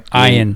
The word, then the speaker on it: Ian: In the last movie, Sharknado 5 Global Swarming, Finn, played by Ian Ziering, did I say that one right? – Ian. – He pronounces it Ian.